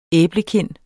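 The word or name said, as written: æblekind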